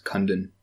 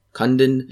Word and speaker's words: Kundun: I think it's pronounced Kundun. – Kundun.